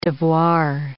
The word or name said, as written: devoir